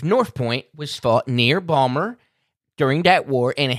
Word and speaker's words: Baltimore: What do you mean by, as I would say, It's how you say it. It's Bawlmer. Okay, well, I mean, you know, I think some people might say that you pronounce it Baltimore. Yeah, you know who says that? People that ain't from Bawlmer.